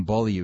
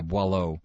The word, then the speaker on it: Boileau: Oh, by the way, Julian, surprised that as a Canadian, you butchered the pronunciation of Boileau. I was calling him Beaulieu because I was thinking of Nathan Beaulieu for some reason. Yeah, Boileau is the name, and it kind of... Bois de l'eau literally means drink water.